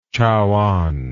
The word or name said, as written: Chaoan